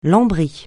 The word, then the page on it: lambris: francais